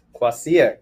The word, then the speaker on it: Kvasir: We're here to talk about, I hope I pronounce this right, Kwassier. – Kwassier. – Kwassier. – Yeah. – Today. – People can call it Kwassier, like KV. It's spelled KVASIR.